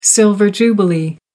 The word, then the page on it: silver jubilee: American English